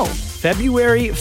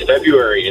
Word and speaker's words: February: It's February. It's like Wednesday. You've never pronounced it like that. – I'm with you, man. – Good point. – I am with you. I'm going to just say it should be a silent R.